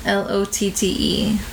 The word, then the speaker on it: Lotte: She acted in, I don't know how to say this, in a latte, LOTTE. Lotte Water Park CF.